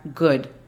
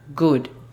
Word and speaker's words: good: Además, si tienen sus notas a la mano, noten la pronunciación de la palabra good. Sabemos en general que se escribe G-W-D, pero quiero que la escriban como si estuviese escrita G-U-D y hagan esa pronunciación, good.